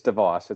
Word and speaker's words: DeVos: I think Betsy DeVos was the richest. – Betsy DeVos, actually. – Yeah, DeVos. However you want to say it. Yeah, it's DeVos. It's a Dutch name.